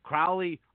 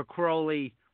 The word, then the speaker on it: Crowley: George Norrie himself said that Crowley or Crowley were both acceptable pronunciations, so I'm taking advantage of both.